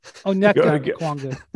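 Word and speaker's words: Onyeka Okongwu: Number six, Okenga Okwongwu. – It's just the worst pronunciation of his name of all time. – Okay. Onyeka Okwongwu. Sorry.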